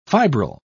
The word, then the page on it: fibril: British English